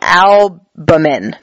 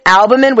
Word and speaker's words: albumin: So it's water, it's albumium or albumium. Oh wait, bumin? I'm saying it wrong. Albumin. There we go. It's albumin, which is like a protein in your body.